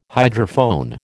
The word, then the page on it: hydrophone: American English